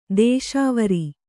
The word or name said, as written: ದೇಶಾವರಿ